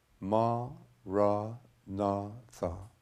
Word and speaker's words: Maranatha: If you choose this word, say it as four syllables, ma, ra, na, tha.